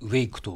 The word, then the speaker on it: WAIC: すねはい 2013 年 8 月 18 日、イクがアクセシビリティ方針作成ガイドラインを更新。こちらもお願いします。 – はい、 WAIC と書いてイクと読みます。